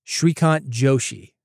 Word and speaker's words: Shrikant Joshi: The host, Shrikant Joshi, and I'm going to spell it for you.